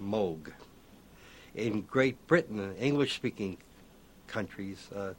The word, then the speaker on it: Moog: He pronounces it Moog.